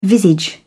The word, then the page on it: visage: British English